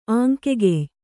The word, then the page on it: ಆಂಕೆಗೆಯ್: āŋkegey